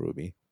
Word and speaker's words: RWBY: Is that pronounced as Ruby, RWBY? – I believe so, but then there's a dispute about that. But I do believe it's called Ruby.